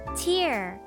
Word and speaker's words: tear: 気になるところですが嘘の涙ではなく純粋な本当の気持ちが相手に伝わりますようにでは今日のワンポイント英語はこちら Tear 涙という意味です実はこの涙の Tear と同じスペル tear で発音の違う tear という言葉があります tear の方は破る引き裂く